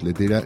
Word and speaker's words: Lettera: Letera, Letera, se escribe L-E-T-T-E-R-A 22.